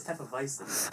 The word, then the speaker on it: Hefeweizen: Juan also said Hefeweizen beer. It's Hefeweizen.